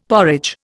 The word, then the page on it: porridge: Indian